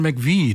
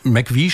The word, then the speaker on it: McVie: Und McVie schreibt sich MCVie, aber das spricht man ja so nicht aus, sondern deswegen wurde es einfach Mac, M-A-C geschrieben.